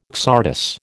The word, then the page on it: csardas: American English